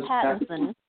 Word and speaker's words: Pattinson: Now, I'm not for sure how Robert, what do you say his name? Patterson or whatever Pattinson. – Pattinson or whatever, from Buffy to Twilight Slayer, he's going to do in it.